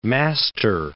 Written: master

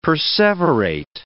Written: perseverate

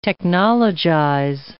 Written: technologize

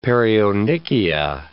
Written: perionychia

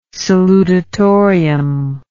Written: salutatorium

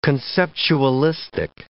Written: conceptualistic

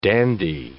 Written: dandy

